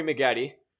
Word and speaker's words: Maggette: This week, Corey Maggette, Bucks legend, joins. – Wonderful. Rhymes with spaghetti.